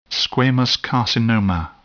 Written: squamous carcinoma

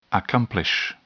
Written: accomplish

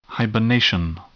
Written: hibernation

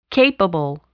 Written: capable

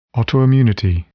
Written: autoimmunity

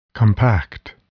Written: compact

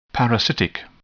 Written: parasitic

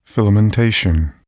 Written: filamentation

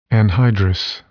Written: anhydrous

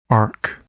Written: arc

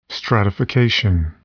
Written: stratification

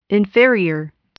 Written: inferior

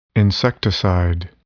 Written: insecticide

